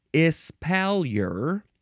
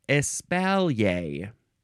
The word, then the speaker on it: espalier: Next is espalier or espalier. Either one. It is spelled E-S-P-A-L-I-E-R. Espalier is the American way to say it. Espalier is probably the more proper French pronunciation.